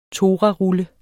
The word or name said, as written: torarulle